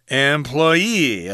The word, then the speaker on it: employee: Employer, employee. Okay, so if you're the person who works at a certain place, then put that accent on the final syllable.